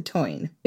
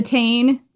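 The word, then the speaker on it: Táin: We did an episode about the cattle raid of Cooley, and throughout it, we called it the tain, which is not the correct pronunciation as far as I can tell.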